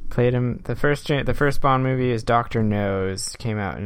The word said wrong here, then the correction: Dr. No: I realized the way I pronounced that made it sound like Dr. No's. – Yeah, I was picturing him having like a really big nose. – It's Dr. No, N-O.